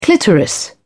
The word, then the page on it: clitoris: British English